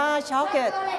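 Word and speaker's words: chocolate: แต่ภาษาอังกฤษเรออกเสียงว่าช็อกเกตช็อกเกต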